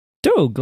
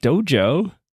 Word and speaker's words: Doge: No, I do GIF with a hard G, but Doge, like dog. Doge? Not like dojo.